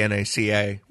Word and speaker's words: NACA: One thing I read said that they did not pronounce it out. – Oh, really? – When it was NACA, it was always said the NACA. – Yeah. – They didn't start, and NASA was the same way for the first couple of years.